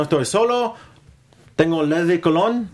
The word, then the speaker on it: Colón: En, español es Colón, como perfume. Sí, como perfume.